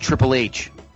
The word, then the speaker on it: Triple H: And because of that, you would like to use a wish to meet Mr. Ha Ha Ha? – Fucking people. It's pronounced Triple H.